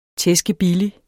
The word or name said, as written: tæskebillig